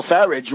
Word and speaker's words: Farage: If you looked at old Nigel Farage, rhymes with garbage, at the beginning of the evening, he said, oh, I think we probably lost.